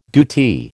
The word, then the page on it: DoT: American English